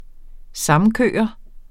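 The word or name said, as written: SAMKØRER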